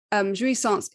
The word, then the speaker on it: jouissance: And I think you refer to it in the book as jouissance, I believe is how you say it.